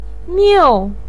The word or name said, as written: miu4